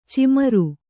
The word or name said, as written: ಛೀಮಾರಿ